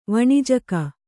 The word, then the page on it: ವಣಿಜಕ: Kannada